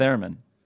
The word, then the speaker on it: theremin: We need a theremin. – A what? – Podcast of Doom. Or theremin. How do you pronounce it? – A theremin.